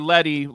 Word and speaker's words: Letty: And Parvati's like, Lete has been in charge the last three, or Letty, let's say her name right, because we do know how to say it.